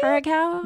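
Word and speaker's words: curaçao: Is that, if I'm saying that right? – I think that's how you say curacao.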